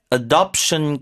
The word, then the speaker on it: adoption: 그리고요, pet adoption campaigns. 자, 입양 단어 발음도 알려드릴게요. – Pet adoption. – Pet adoption. – Adoption. – Adoption. 어가 아니라 의에 가까워요. 뒤에 강세니까.